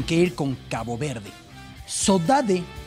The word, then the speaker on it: sodade: Forma de decir sodade, una palabra que en Brasil conocemos mucho, saudade, saudade, es un término portugués que así se pronuncia en el archipiélago.